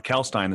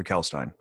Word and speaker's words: Kalkstein: Kalkstein, the second K is silent. Dr. Kalkstein.